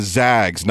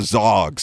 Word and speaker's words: Zags: They're the Zags, not the Zogs.